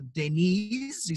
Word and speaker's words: Deniz: So, okay, if we're going to get into the terminology of Turkish, to Deniz is how you pronounce my name.